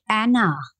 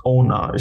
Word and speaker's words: Anna: Yeah, I think she even, her actual pronunciation is Ona. She calls herself, everyone calls her Ona.